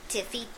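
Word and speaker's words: Te Fiti: The land one is Tefiti. – Tefiki. – Tefiti. – Tefiti? – Yes. – Tefiti. – Yes. – Oh, okay. – You have the wrong emphasis on the wrong syllable.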